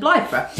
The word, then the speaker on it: Blythburgh: Is that how you pronounce it? Blythburgh? – Yeah. Blythburgh. Yeah.